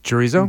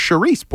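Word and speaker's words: chouriço: Yeah, the chorizo? Mm-hmm. – No, it's charisse Portuguese. – Oh. Mm. Oh, that's how they say it in Portuguese.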